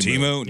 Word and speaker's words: Temu: The amount of money Temu has. There were so many. – Oh, yeah. – Oh, yeah. – Also, I've been saying Temu wrong. I've been saying Temu. – Temu? No, it's Temu.